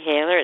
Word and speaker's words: Hayler: It's Freddie Haler. It's spelled F-R-E-D-D-Y, and then new word, Haler, H-A-Y-L-E-R.